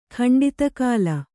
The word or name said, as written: ಖಂಡಿತಕಾಲ